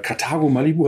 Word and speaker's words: Carthago Malibu: Ähm, herzlich willkommen an den Alex Wehrmann von äh, Carthago Malibu, habe ich das richtig gesagt? Hallo, richtig.